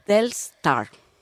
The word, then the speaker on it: Telstar: Y bueno, esto, el balón que se llama Teslar, creo que no lo estoy pronunciando nada bien el nombre, pero bueno, es que no soy muy futbolera. – Teslar. – Telstar. – Ah.